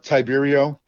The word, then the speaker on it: Tiberio: Last name's uh, Tiberio.